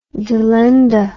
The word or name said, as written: delenda